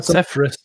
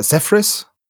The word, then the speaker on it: Zephyrus: Und die Conqueror Sephiris. oder Zephyrus, ja. Spricht man das im Englischen aus.